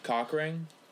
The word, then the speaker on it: Cochrane: I always forget how to say this guy's name. Mickey Cochrane? – I said Cochran. – I think it's Cochran. – Is it Cochran? – Probably is. – Cochran? – It's Cochran. Cochran? – Yeah, fucking Mickey Cochran over here.